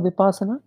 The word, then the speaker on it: Vipassana: Vipassana. – Vipassana. Is it different from Vipassana? – No. That's the way you pronounce.